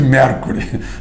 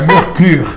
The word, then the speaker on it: Mercure: Tem um hotel que o pessoal chama de Mercury. Não, é Mercure.